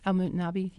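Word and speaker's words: Al-Mutanabbi: So, were you personally familiar with the street Al-Mutanabi? Is that how it's pronounced? – Al-Mutanabi. Yes.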